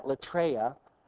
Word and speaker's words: latreia: For those of you who like to see Greek, you pronounce that latreia.